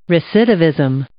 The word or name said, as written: recidivism